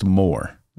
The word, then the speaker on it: Mawer: Even though it's spelled M-A-W-E-R, it is pronounced Moore. – Yep. – As in more money.